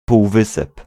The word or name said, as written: peninsula